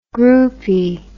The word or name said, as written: groupy